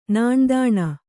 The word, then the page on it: ನಾಣ್ದಾಣ: Kannada